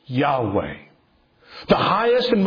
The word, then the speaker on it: Yahweh: Yahweh, YHWH, that's pronounced Yahweh.